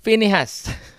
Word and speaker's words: Phinehas: But Pinhas, how do you pronounce? Phinehas, sorry.